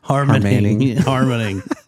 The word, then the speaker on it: Harmening: Did I say your last name right this time? Harmening. – Harmening.